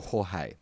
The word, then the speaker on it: Jorge: Hey, gents, I thought this BT would call him George. the official pronunciation might be Jorge, but this is bloody Australia, mate, and it's Fantasia, and it's fucking George, mate.